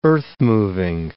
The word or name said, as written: earthmoving